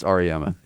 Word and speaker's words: Auriemma: I can never pronounce his last name. – Auriemma. I believe it's Auriemma.